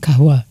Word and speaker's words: Kahwa: Genau, mit einem Kahwa.